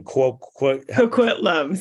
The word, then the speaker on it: Coquitlam: Coquitlam.